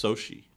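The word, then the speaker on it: Xochitl: Well, it's pronounced Sochi. – Oh, I have her in class too. – It's like XOXOCHITL or something. – Nice. – Yeah, and when I try to pronounce it the first day, she said, I go by Sochi, and I'm like, where we had the Olympics?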